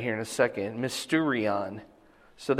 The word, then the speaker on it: mysterion: Okay, so mysterion is the way it's pronounced here.